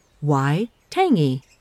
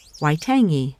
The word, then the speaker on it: Waitangi: Though I am told it's a soft G, so the name sounds kind of like describing the reason I might love a tasty morsel. Why tangy? Waitangi.